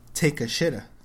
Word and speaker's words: Takeshita: Yoshie Takeshita.